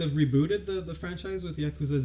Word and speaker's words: Yakuza: It's Yakuza.